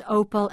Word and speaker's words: Opel: Opel in business, while the long-term details of any kind of deal for General Motors' European arm are wrapped up. – And we remind ourselves, GM Europe owns Opel and Vauxhall, have I said that correctly? – That's correct.